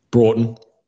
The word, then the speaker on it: Broughton: In South Australia, Port Broughton, how you pronounce that? Broughton Jetty? – Broughton.